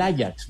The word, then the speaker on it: Ajax: Eh, pues sí, no, pues se escribe Ajax, pero es el Ajax, güey.